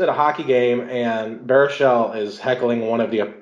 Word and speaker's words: Baruchel: Baruchel. – Baruchel.